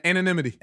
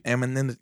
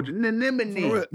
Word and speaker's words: anonymity: Anonymity. – Amininity.